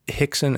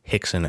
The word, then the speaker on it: Rickson: Hickson and Holes trained together. Now, just so you know, in Portuguese, they pronounce R's as H's. So Rickson and Rolls are Hickson and Holes.